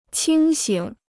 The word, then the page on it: 清醒: qīng xǐng